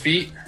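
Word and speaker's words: fête: It's F-E-T-E. Is that just Feet? Yeah? – Yes.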